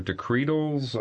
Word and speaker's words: decretals: Well, Deacon, can you go back and what is that word? Decretals or decretals? How do you spell that? – Decretals, it's D-E-C-R-E-T-A-L-S.